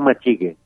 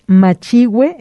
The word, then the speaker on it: machigüe: Y con diéresis en la U, si no diría machigue, ¿no? – Exacto, exactamente. Entonces, machigüe es la pronunciación más correcta, machigüe con G y con diéresis.